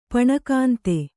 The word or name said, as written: ಪಣಕಾಂತೆ